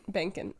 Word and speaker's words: Banken: Banken. It's spelled B-A-N-K-E-N.